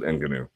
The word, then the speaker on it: Ngannou: Okay, well, people who want to pronounce it that way can. I'm going to pronounce it Ngannou.